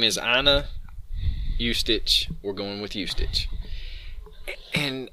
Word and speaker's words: Ustich: U-S-T-I-C-H. Ustich is how I would say it. – I'm not sure. – And it's Anna. – Well... – Okay. Her name is Anna... Ustich. – Yeah, I believe that's how you say it.